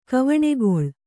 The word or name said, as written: ಕವಣೆಗೊಳ್